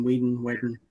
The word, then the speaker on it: Whedon: Is that how you pronounce the name? Whedon? – Yeah.